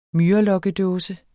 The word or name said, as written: myrelokkedåse